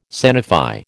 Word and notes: sanify: American English

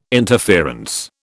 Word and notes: interference: British English

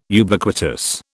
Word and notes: Ubiquitous: British English

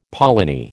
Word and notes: polony: American English

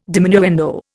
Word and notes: diminuendo: Indian

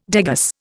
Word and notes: degas: Indian

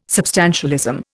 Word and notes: substantialism: Indian

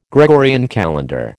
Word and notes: Gregorian calendar: American English

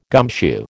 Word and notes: gumshoe: American English

